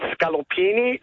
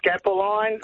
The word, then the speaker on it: scallopini: They couldn't pronounce veal scallopini. They would call it veal scapoline, veal scapolini.